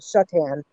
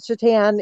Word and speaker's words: Chetan: The problem is some people put I in there and there's no I's in there, so I usually tell people to pronounce it she tan, like she tans at the beach. And that's just the easiest way, but people who know me for a while will pronounce it Shutan, with a sh in there. So different pronunciations. It depends on your accent and where you are in the country. So but Shutan is fine.